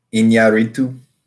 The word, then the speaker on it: Iñárritu: Iñárritu.